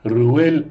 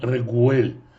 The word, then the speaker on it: Реуэль: В книге Бамидбар, по-моему, в 10 главе, Реуэль назван Регуэль. Почему? Потому что буковка Айн, она в некоторых диалектах читается не как А, а как Г.